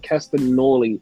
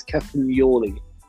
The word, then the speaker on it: Castagnoli: I've been saying Castagnoli, and it's Castagnoli.